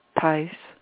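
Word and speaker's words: Thaïs: Meditation from Thais. – Thais.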